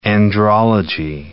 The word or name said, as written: andrology